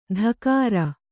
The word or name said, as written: ಧಕಾರ